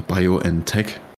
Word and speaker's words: BioNTech: Ich hatte dann das Privileg noch BioNTech oder BioNTech, wie man es ja eigentlich aussprechen sollte, äh, bekommen.